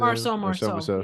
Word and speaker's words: Marceau: Marceau. Yeah. – Yeah. – But that was a stage name. – Well, true. I wonder if Evan... – But it's Marcel Marceau. So it's not Marcel Marceau.